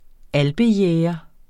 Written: alpejæger

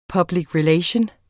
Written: relation

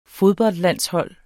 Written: fodboldlandshold

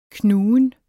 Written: knugen

